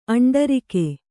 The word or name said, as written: ಅಂಟರಿಕೆ